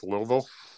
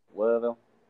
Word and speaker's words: Louisville: To be fair, it's actually pronounced Louisville. – Louisville.